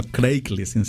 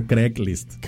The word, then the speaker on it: Craigslist: No Craigslist, se dice Craigslist. Craig. Como si fuera Greg, pero con C.